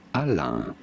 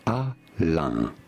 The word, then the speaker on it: Alain: Alain. Alain. Alain is how it's said in France. Alain.